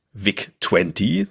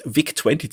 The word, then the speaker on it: VIC-20: Denn der Commodore Deutschland-Chef, der Harald Speyer, der warnt die US-Kollegen, den Rechner hier vielleicht besser nicht VIC-20 zu nennen, denn je nach Aussprache könnte das unflätig klingen, wenn man das V halt wie ein F ausspricht.